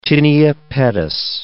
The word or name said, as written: tinea pedis